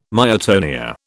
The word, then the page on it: myotonia: British English